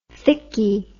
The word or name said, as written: thickie